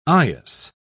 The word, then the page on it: eyas: British English